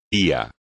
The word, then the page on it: pío: espagnol